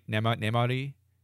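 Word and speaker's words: Namárië: Namari. Isn't that how you say it? Namari? – Namari.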